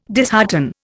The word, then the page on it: dishearten: Indian